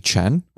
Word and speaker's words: Chen: It's simply just my full name, Anthony Chen. Last name is spelled C-H-E-N.